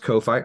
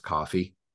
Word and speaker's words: Ko-fi: it's pronounced ko-fi i'm not going to lie i legitimately thought it was pronounced coffee because there I,